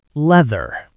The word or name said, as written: leather